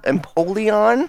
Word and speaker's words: Empoleon: We're getting Empoleon.